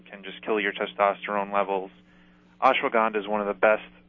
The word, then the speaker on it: Ashwagandha: Ashwagandha and testosterone levels.